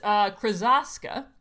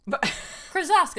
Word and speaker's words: Krosoczka: Krasoska. – Not bad. It's Krasoska, rhymes with Oska. Krasoska. That's how he says it. – Okay. – Like Sheska rhymes with Fresca.